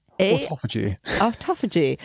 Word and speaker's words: autophagy: Autophagy, or however you pronounce it. Very good, yeah. Autophagy. – Yes, it's auto, A-U-T-O, and then phagy, P-H-A-G-Y.